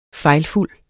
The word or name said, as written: fejlfuld